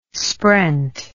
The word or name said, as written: sprent